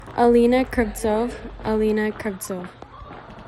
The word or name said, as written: Alina Kravtsov